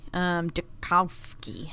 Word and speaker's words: Duchovny: Duchovny.